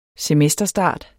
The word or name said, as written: semesterstart